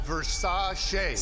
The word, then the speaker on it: Versace: Pronounced Versace.